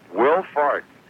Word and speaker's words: Wilfahrt: There's a big polka guy named Johnny, was his first name, and his second name was spelled Wilfahrt. Tell me how you would pronounce that. – Wilfahrt. – That was right.